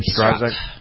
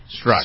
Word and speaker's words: Strzok: Is how you pronounce his name? – Strzok. – Strzok. – Strzok. – Okay.